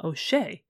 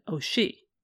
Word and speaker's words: O'Shea: Her husband William disliked the pronunciation of O'Shea and personally said O'Shea.